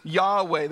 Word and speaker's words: Yahweh: The word translated Lord in our English Bibles comes from the Hebrew word, which we pronounce as Yahweh, the personal name of God, which is derived from the Hebrew word for to be, to exist.